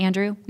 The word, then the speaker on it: Andrew: And Andy is spelled with an I, A-N-D-I. Mm-hmm. Andrew. No S on the end.